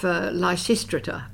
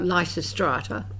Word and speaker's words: Lysistrata: Well, before that, there was a production at the Old Tote of Lysistrata, or Lysistrata, whichever pronunciation you prefer, with an amazing cast of people.